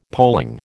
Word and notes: polling: American English

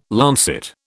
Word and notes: lancet: British English

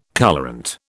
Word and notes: colourant: British English

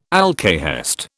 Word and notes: alkahest: British English

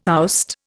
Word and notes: oust: Indian

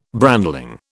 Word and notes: brandling: British English

